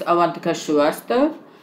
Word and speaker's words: Avantika Shrivastava: For your listeners, my name is Avantika Shrivastava, and I am currently, yes, in New Delhi, India.